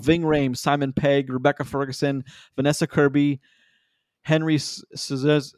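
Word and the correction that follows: Czerny: Zerny, sorry, I mispronounced that one, as Eugene Kittredge, who is back from the first Mission Impossible in 1996.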